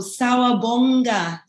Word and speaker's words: Sawubona: Sawubona. – Oh, that is so beautiful. Sawubonga is how we say it.